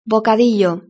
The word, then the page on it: bocadillo: Spain Spanish